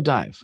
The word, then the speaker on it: DIIV: Dive. – I don't know Dive. – They spell it D-I-I-V.